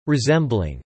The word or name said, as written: resembling